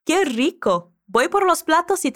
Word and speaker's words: rico: Quando você ouvir de novo o áudio dos nativos, eu quero que você repare como a Lola pronuncia esse R no início da palavra. É algo como rico, rico.